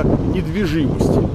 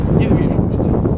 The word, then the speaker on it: недвижимость: Почему, Свет, хочется сказать иногда недвижимость? Поэтому хочется что-то неправильное. Да, друзья, всегда хочется что-то неправильное делать. Да, будем говорить правильно. Будем говорить недвижимость.